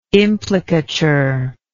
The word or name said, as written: implicature